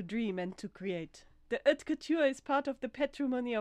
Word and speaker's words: haute couture: I was doing so well with all my pronunciation and then I stuff up haute couture. He was asked where haute couture fit within French culture.